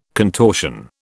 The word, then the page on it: contortion: British English